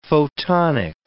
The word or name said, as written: photonic